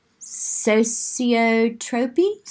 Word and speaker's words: sociotropy: People pleasing is associated with a personality trait called sociotropy.